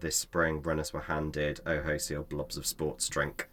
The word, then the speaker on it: OOHO: And they called the packaging, and again, it's pronounced Oho, but it's spelled O-O-H-O, mm-hmm. – which is Oho.